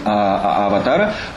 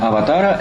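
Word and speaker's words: аватара (अवतार): Это и есть санскритский термин «аватара», который, например, в названии «бодхичарья аватара» может переводиться как «вступление на путь бодхисаттвы». Однако санскритский термин «аватара» – это тот же термин, который на хинди произносится как «аватар», то есть «аватар» в значении воплощения, например, подобно тому, как Кришна является аватарой Вишну.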